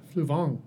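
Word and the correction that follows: Fluevog: Did I put an N in there? There should be Fluvog.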